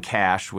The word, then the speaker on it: Cache: calling Cash, which is spelled C-A-C-H-E.